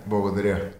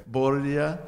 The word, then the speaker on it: Благодаря: Благодаря. – Благодаря.